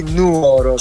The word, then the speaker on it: Nuoro: Allora, oggi parliamo dell'annoso problema di accento che sì, contraddistingue la città di Rullo di Tamburi Nuoro. – Ah, l'hai detto giusto. Avevo un po' di ansietta nel pronunciare Nuoro, Nuoro, esatto. Non Nuoro.